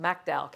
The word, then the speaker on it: McDowell: But I had been down there, and I met a woman in, well, it's McDowell, we would say McDowell, but down there it's pronounced McDowell County, West Virginia.